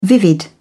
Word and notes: vivid: British English

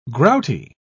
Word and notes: grouty: British English

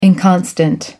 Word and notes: inconstant: American English

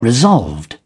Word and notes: resolved: British English

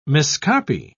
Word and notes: miscopy: British English

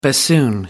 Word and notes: bassoon: American English

